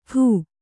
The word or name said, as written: ಠೂ